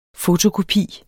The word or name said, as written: fotokopi